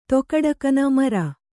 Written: ಮೆರೆ

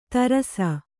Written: ಧಾರಿಸು

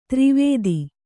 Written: ದುರ್ವಾದ

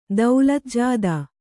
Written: ದೌಲತ್‍ಜಾದಾ

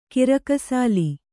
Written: ಕಿರುಕುಸಾಲೆ

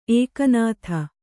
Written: ಎಕ್ಕನಾದಿ

